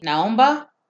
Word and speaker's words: Naomba: How do you say N-A-O-M-B-A? – Naomba? – Naomba.